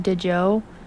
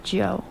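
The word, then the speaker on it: Djo: Joe Keery, but it's like D J O. I call him DeJo, but yes. it's pronounced Joe. I learned that the hard way.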